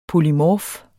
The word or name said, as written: polymorf